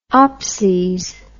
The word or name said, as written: opses